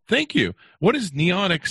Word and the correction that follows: Noetic: Sciences? Did I even pronounce that right? – I'm sorry. The Institute of Noetic Sciences. – Noetic. – It was founded by the late astronaut Edgar Mitchell. – Noetic. All right, there you go.